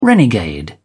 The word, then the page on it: renegade: British English